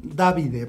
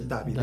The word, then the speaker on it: Davide: Bueno, Davide, porque en italiano se dice Davide.